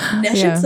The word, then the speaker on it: nescience: So the purpose of the Bhagavad Gita is to deliver mankind from the niche science of material existence. – What does that mean? Nescience of material existence.